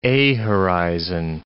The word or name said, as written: A horizon